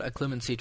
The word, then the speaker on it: Occlumency: How do you pronounce it? Occlumency? – Occlumency.